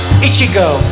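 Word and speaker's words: Ichigo (苺): In Japan, the word strawberry, you say it like this, Ichigo.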